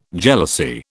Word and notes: jealousy: British English

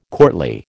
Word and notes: courtly: American English

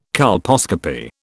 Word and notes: colposcopy: British English

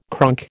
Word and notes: cronk: American English

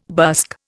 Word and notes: busk: Indian

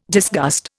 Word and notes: disgust: Indian

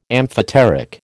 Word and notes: amphoteric: American English

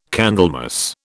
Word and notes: Candlemas: British English

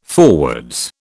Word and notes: forwards: British English

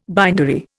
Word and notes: bindery: Indian

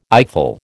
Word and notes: eyeful: American English

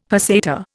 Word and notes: peseta: Indian

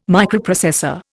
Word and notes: Microprocessor: Indian

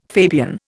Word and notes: Fabian: Indian

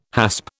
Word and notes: hasp: British English

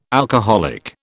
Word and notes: alcoholic: British English

